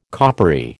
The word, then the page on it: coppery: American English